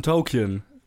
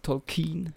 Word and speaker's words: Tolkien: Wir reden über den Film Tolkien.